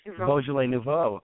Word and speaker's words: Beaujolais Nouveau: How do you say it? – Beaujolais Nouveau. Oh, good.